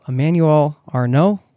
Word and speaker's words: Emmanuel Arnaud: I can give you the French way, Emmanuel Arnaud.